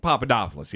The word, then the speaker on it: Papadopoulos: Did I say his name correctly by the way? – Papadopoulos, yeah.